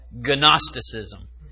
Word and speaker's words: Gnosticism: And it's spelled Gnosticism. So it starts with a G.